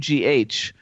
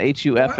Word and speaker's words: Hough: But if remember that Hough is Hough. And not Huff.